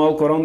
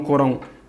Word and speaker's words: Alcorão: Então, o Alcorão, nesse sentido, é isso. Então, para mim, pessoalmente, a, a, a pronúncia, né, a escrita correta é Alcorão e não Corão somente.